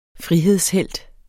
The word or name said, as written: frihedshelt